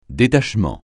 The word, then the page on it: détachement: francais